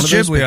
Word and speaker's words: Ghibli: You know, and it shows it is Ghibli. I looked up the pronunciation. Yeah, in Japanese, it's written, the first syllable is G. – Oh, Ghibli. – Yeah, it's Ghibli.